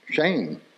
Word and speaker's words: Shem: But the word for name, the original Hebrew word, is pronounced shame. So the word name in Hebrew actually means shame. So when we're born and we get our earthly name, our name actually means shame. I mean, it's spelled Shem.